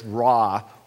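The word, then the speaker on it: ra (רע): In Hebrew, it's one of the shortest Hebrew words, just two letters, ra, we would pronounce it.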